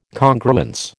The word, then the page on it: Congruence: American English